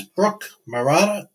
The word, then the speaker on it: Brooke Maratta: Her name is Brooke Maratta. Did I pronounce that right? – Yeah, it is. Yes.